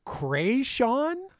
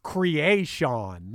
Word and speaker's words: Kreayshawn: And is it Kreayshawn? – I think you're saying it right, because however I'm saying it, it's usually wrong.